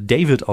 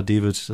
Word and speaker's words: David: Ich heiße David, aber eigentlich kann man es auch David aussprechen und man könnte es aber auch äh, David aussprechen. Also ich äh, habe das gleiche hier in Deutschland mit meinem Vornamen. Ich sage dann immer, ist egal, ich sag einfach David.